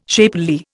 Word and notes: shapely: Indian

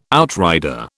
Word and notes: outrider: British English

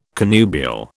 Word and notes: connubial: British English

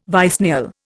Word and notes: vicennial: Indian

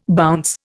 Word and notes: bounce: Indian

